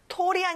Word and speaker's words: tall: 자, tall이 아니라 어 소리가 나요. tall.